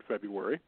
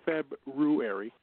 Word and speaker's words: February: This is probably the most, I think, um, hardest month to pronounce on the regular I know it's February. My Chicago accent will say February at times, but I know it's February. It should be February because that's how it's spelled.